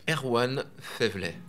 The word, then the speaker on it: Erwan Faiveley: Erwan Faiveley.